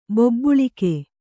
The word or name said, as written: ಬೊಬ್ಬುಳಿಕೆ